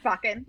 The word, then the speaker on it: Bakken: Like mine for in this book, and I don't know why I fixated, is I think Bakken, is that how you say it? Bakken? – Bakken.